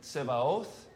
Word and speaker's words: Tsebaoth: Yahweh Tsebaoth, which we usually say Sabaoth, right? S-A-B-A-O-T-H.